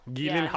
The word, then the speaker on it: Gyllenhaal: The actual pronunciation of... – Gyllenhaal? – Gyllenhaal. – No one says it like that. – Because, well, the Swedish do.